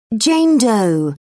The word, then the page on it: Jane Doe: British English